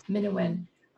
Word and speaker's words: Minuin: We were so looking forward to bringing your latest work, Minuin. Am I saying that correctly? – Yes. – Minuin.